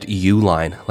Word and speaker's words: Uline: My last name is pronounced Uline, like the shipping company.